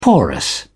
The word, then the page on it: porous: British English